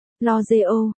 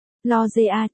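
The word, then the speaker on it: L'Oréal: Dù sao, lúc mới tới tôi luôn đọc là L'Oreal, sau này mới biết phải đọc là L'Oreal chữ A, đó từ đâu chui ra cơ chứ?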